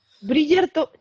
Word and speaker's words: Bridgerton: Como pues si Tom brillara, Bridgerton. Ah, Bridgerton, ya está. – Bridgerton. – Ya está, Bridgerton, me gusta.